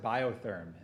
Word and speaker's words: Biotherm: And we pronounce it biotherm in the US.